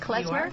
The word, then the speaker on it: klezmer: Klezmer? – You are. – That you started. Mm-hmm. And again, it was a first of its kind in the U.S. I don't want to describe what klezmer music is.